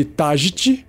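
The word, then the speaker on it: Taggit: O óleo de Tajit, que se escreve T-A-G-G-I-T, é um veneno por contato.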